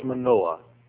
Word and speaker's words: Manoah: There's a fellow whose name was Manoah, pronounced just like the word Noah with a M-A before it, Manoah.